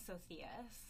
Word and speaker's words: Socias: I'm Sydney Socias. My last name is Spanish. No one can pronounce it.